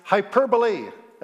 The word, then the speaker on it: hyperbole: We recognize hyperbole. That's a great word. Hyperbole, I used to say, and said it wrong every time.